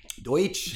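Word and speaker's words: Deutch: But I went with, and I hope I pronounced her last name correctly, Zoe Deutsch. – Deutsch.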